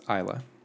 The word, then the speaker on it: Isla: Name is pronounced Isla. – Isla. – Like Isla Fisher.